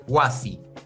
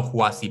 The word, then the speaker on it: Huasi: Huasi, no Juasi, perdón. Julio Huasi, porque no es con J.